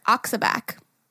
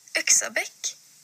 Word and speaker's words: Yxabäck: I would say that as Axibak.